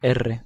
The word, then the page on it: r: Spanish